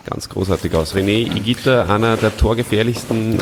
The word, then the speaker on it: Higuita: Das ist ein Verwandter von dir, der Higuita. – Nein, das ist ein Grundfalsch. – Higuita. – Higuita ist ein Grundfalsch. Er heißt Higuita, weil das H ist stumm und das G-U spricht man als G aus.